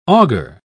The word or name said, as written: augur